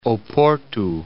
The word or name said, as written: Oporto